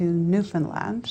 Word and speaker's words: Newfoundland: Newfoundland.